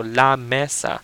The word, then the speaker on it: la mesa: La mesa, how do you spell it? L A M E S A. Now, in Spanish, that would be la and then mesa because it's a la is like an article for female objects, feminine objects, and mesa is the word for table.